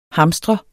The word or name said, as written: hamstre